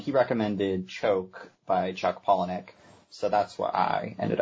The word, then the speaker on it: Palahniuk: Palahniuk.